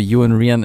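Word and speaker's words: Iwan Rheon: Ja, ähm, also es gibt ganz viele verschiedene Aussprachen äh, von Ivan, Ivan, Ewan, und bist noch ganz verrückte andere Dinge, die ich gehört habe. Ich glaube, Ewan Rian ist ähm, so, wie er sich auch selber nennt.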